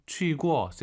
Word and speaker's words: 去过: 去过 세게 하지 마시고 뚝 떨어뜨리세요.